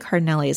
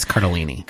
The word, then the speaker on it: Cardellini: I think it's Cardellini. – Cardellini. Is it? – Cardellini. I looked at it myself. – Okay. – I've also always said it wrong.